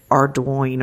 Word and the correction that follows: Ardoin: I live in Lake Charles where Pizzolatto grew up and that is a really common surname, but it's pronounced Ardoin rhymes with swan.